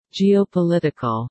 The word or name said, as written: geopolitical